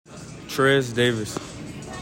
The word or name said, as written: TREZ DAVIS